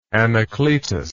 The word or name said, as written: anacletus